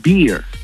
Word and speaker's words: beer: Porque con, o sea, la barba se pronuncia beard, el oso se pronuncia bear, ¿eh? Y la cerveza se pronuncia beer. – ¿Eh? – Pero con R al final.